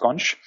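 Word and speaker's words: xonsh: Yeah, with an X. – There's an X, but it's pronounced Sconch.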